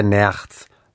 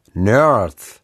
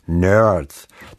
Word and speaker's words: Nerds: Nein, nicht der Nerz. Nerds. Wobei ich das R nur ganz leicht anrollte. Nerds.